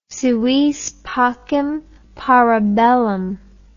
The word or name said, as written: si vis pacem para bellum